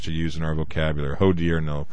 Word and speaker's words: hodiernal: Hodiernal. H-O-D-I-E-R-N-A-L. Hodiernal is how you pronounce it, I believe.